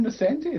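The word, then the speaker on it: Nocenti: Is it not Ann Nascenti? Is that not how you pronounce her name? – Yeah, it is.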